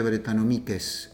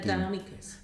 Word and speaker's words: Brettanomyces: Se pronuncia Brettanomyces en latín. – Brettanomyces. – Pero bueno, van a seguir diciendo Brettanomyces. No te preocupes. – Es la españolización. La palabra es completamente latina.